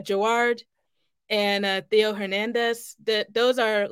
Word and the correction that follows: Giroud: Giroud Hernandez.